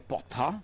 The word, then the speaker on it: Potter: Allora, innanzitutto spieghiamolo un attimino, visto che non è che io non ho capito, è che allora o le parole me le pronunciate correttamente. – Perché volete fare i fenomeni di Harry Potter. – Ah, Harry Potter. – Ok, se lui avesse detto cosa ti piace ascoltare, Potter, allora avrei capito che si trattava di Harry Potter.